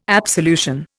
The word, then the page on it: absolution: Indian